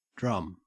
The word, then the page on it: drum: Australian English